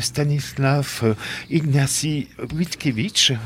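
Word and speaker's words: Stanisław Ignacy Witkiewicz: Il s'agit de Stanislav Ignacy Witkiewicz.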